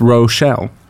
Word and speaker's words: Rochelle: Rochelle, can I call you Rachel? – Well, that's not my name. – It's Rochelle. – Yeah. – Oh, it's spelled with an O.